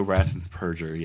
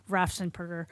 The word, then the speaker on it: Raffensperger: And then when I heard the other election official talk about Raffensperger the other day, then I realized that was how you pronounce his name.